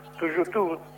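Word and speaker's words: Tuyutí: es el aniversario de la batalla de Tuyutú, porque en realidad se pronuncia así, de la guerra entre Brasil, Argentina y Uruguay contra Paraguay.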